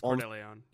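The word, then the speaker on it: Cordileone: We can admit, like, Bishop Cordiglione, I don't know how to say his name. – Bishop Cordeleon.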